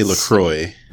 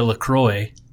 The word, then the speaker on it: LaCroix: LaCroix? LaCroix? How do you say it? – I say LaCroix.